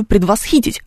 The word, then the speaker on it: предвосхитить: Здесь неправильное ударение. Но ты-то знаешь, что оно правильное. Соответственно, корректор, который будет тебя править, тоже должен знать, что правильное ударение в этом слове «предвосхитить», а не ты его придумал.